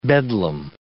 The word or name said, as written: bedlam